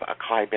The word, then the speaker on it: acai: They talk about the good things to eat and that you should eat organic and that you should have acai berries and you should...